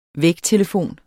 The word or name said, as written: vægtelefon